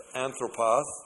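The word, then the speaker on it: anthropos (ἄνθρωπος): A-N-T-H-R, long O-P-O-S, anthropos.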